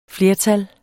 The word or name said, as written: flertal